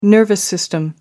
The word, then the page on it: nervous system: American English